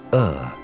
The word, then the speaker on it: Ur: It started in a place called Ur, which rhymes with fur, ruled by a people called the Chaldeans, which rhymes with Schmaldeans.